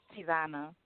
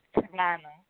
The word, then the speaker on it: Cevona: Tivana. – Brooklyn. And can you say your name a little louder? I can't quite make your name out. – Tivana. Tivana. C-E-V-O-N-A.